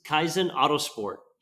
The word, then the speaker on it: Kaizen Autosport: I am here with Peter Heffring, and Peter is the owns Kaizen Autosport.